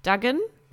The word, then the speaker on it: Duggan: Äh, Reihenfolge des Draft, gibt es Ausnahmen und wo geht Max, wie spricht man seinen Namen an, äh, aus, Daniel? Duggan? – Ganz korrekt, genau.